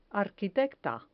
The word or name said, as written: arquitecta